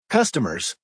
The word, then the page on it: customers: American English